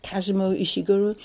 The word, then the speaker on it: Kazuo Ishiguro: Is it Kazuo Ishiguro? – Yes.